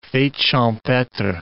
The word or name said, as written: fete champetre